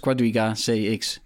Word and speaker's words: Quadriga CX: Hoe spreek je dat uit, jongens? Quadriga CX. – Ja, dat is goed volgens mij.